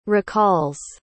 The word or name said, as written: recalls